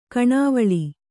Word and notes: ಕಣಿವೇಳು: kaṇivēḷu

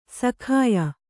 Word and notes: ಸಖಾಯ: sakāya

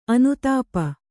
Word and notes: ಅನುತಾಪ: anutāpa

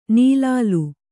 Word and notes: ನೀಲಾಲು: nīlālu

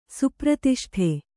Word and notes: ಸುಪ್ರತಿಷ್ಠೆ: supratiṣṭhite

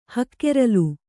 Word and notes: ಹಕ್ಕೆರಲು: hakkeralu

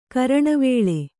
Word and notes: ಕರಣವೇಳೆ: karaṇavēḷe

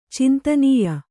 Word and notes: ಚಿಂತನೀಯ: cintanīya

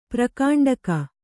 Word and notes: ಪ್ರಕಾಂಡಕ: kannada

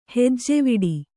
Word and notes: ಹೆಜ್ಜವಿಡು: hejjviḍu

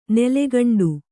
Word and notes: ನೀಲಕಂಠ: nīla kaṇṭha; kannada

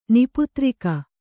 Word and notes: ನಿಪುತ್ರಿಕ: Kannada

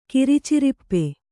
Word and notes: ಕಿರಿಚರಿಪ್ಪೆ: Kannada

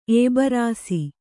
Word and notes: ಎಬ್ರಾಸಿ: ebrāsi

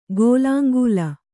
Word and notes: ಗೋಲಾಂಗೂಲ: gōlāŋgūla

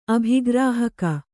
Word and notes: ಅಭಿಗ್ರಾಹಕ: abhigrāhaka

